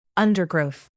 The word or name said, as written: undergrowth